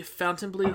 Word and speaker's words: Fontainebleau: He owned the Fontainebleau Hotel in South Miami Beach, where Frank Sinatra, Esther Williams, and Marilyn Monroe had all stayed.